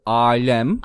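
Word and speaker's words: âlem: So, and this word for universe, alem. – Alem. – And you can hear it. Alem. – Alem. Aynen öyle. – So, long A.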